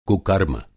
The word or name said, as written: कुकर्म